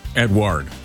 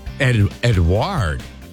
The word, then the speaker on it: Edouard: Edouard. That's not how I would spell. Or am I saying that wrong? – Edward. – Edward.